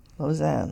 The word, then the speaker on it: Lozen: Lausanne. Hmm. L-O-Z-E-N. You know, it's not always said the way it's spelled. – Yeah. Thank you. I was spelling it the way Lausanne in Switzerland. – Right. – L-O-Z-A-N. – Z-E-N.